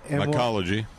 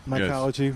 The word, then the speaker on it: mycology: Am I pronouncing that right? – Mycology. – Mycology. – Yes. Yeah.